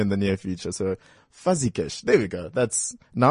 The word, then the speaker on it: Fuzigish: F-U-Z-I-G-I-S-H. Fuzzy Gush.